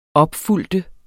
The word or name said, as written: opfølge